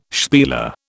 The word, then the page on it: spieler: British English